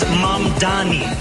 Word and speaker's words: Mamdani: And furthermore, the name is Mamdani. M-A-M-D-A-N-I. You should learn how to say it.